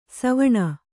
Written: ಸ್ವನ

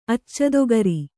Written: ಅಚ್ಚದೊಗರಿ

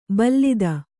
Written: ಭಲ್ಲಾತ

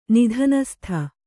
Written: ನಿದಾನಸ್ತ

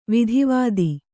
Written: ವೇದವೇತ್ತ

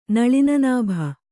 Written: ನಳಿನನಾಭ

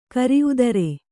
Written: ಕರಿಉದರೆ

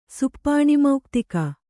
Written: ಸುಪ್ಪಾಣಿಮೌಕ್ತಿಕ

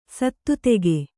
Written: ಸತ್ತುತೆಗೆ

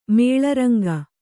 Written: ಮೇಳರಂಗ

